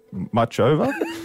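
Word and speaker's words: Muchová: Ash Barty is on court this morning against 25th seed Caroline Muchova.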